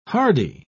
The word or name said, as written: hardie